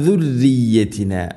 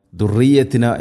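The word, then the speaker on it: ദുർരിയത്തിന (ذريتنا): അതുപോലെ ദാലിൻ്റെ ഉച്ചാരണവും ആ ശബ്ദം സുറിയത്തിന എന്നോ ദുർറിയത്തിന എന്നോ ആവരുത്. ദുർരിയത്തിന റായിനെ തർക്കീഖ് ചെയ്യണം, നേർപ്പിക്കണം. ദുർരി ഇവിടെ അധികം ആളുകളും ദുർറിയത്തിന എന്ന് പറയാൻ അല്ലെങ്കിൽ ഓതാൻ സാധ്യതയുണ്ട്, ശ്രദ്ധിക്കണം.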